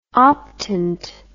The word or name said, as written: optant